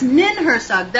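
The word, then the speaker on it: Ninhursag: How you say it. Yes, Nin Hersag, that's right.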